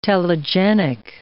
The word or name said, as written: telegenic